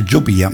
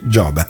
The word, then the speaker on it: Giobia: Fra l'altro ho scoperto che non si dice Giobia, ma si dice Gioba.